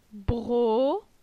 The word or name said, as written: Bro